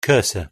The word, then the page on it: cursor: British English